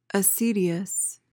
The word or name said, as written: acedious